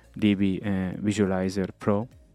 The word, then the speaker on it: DbVisualizer Pro: It's it's about a piece of software um, called the uh, DBVis uh, softwares, uh, D, uh, DB Visualiz Visualizer Pro, DB Visualizer Pro, to pronounce it nicely.